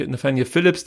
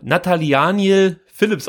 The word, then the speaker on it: Nathaniel Phillips: 1-0 durch Gomez, 2-0 durch Nathaniel Phillips, der übrigens Nata Natalianiel Philips ausgesprochen wird in Großasbach.